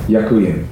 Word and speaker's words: Ďakujem: Ďakujem.